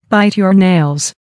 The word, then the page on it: bite your nails: American English